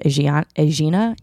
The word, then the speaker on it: Aegina: Aegina, A-E-G-I-N-A.